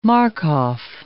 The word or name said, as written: Markov